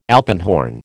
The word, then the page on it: alpenhorn: American English